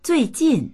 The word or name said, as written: zui4jin4